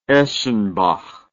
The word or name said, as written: eschenbach